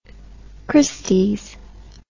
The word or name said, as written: Christie's